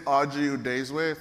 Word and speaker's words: Udezue: First, about me, my name is Udezue, phonetically in front of you.